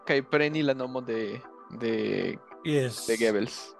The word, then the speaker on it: Goebbels: Que la germana tio pronuncia isso, né? Goebbels. Joseph Goebbels. Ah, estas O com umlauf, né? Isso, né? Es Goebbels.